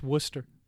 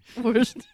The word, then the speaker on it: Worcester: It's pronounced Worcester. – Worcester.